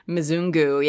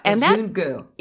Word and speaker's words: Mzungu: Mzungu, yeah. – Mzungu.